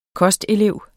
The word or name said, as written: kostelev